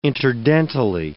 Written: interdentally

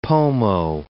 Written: Pomo

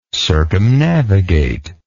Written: circumnavigatory

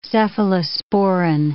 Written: cephalosporin

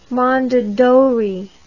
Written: mondadori

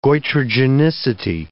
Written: goitrogenicity

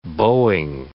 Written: bowing

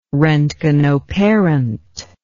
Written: roentgenoparent

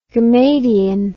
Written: gammadion

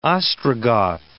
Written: Ostrogoth

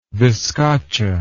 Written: vizcacha

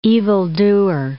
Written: evildoer

